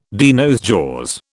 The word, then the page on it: de nos jours: British English